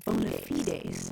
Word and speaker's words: bona fides: I tell you, Marianne, I have always pronounced it bona fides. But you know, there are some authorities who have sneered at that pronunciation, and they say it should be bona fides.